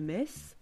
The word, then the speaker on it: Metz: M-E-T-Z, qui se prononce Metz.